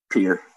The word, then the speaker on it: Pierre: Pierre. Just the like a pier, like a water pier kind of. – That's fucking stupid. I'm going to call it Pierre.